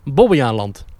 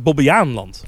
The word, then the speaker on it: Bobbejaanland: Wat me opviel, dat Dennis zegt een beetje Bobbejaanland. Dat hoor ik vaker. – Nee, het is Bobbejaanland. – Voor mij inderdaad, de klemtoon echt op Bobbejaan.